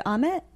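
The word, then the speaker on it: Ahmet: Our network interviewed Amit. – Mm-hmm. – Did I say his name right? – Yes, you did.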